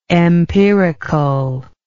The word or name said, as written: empiricalness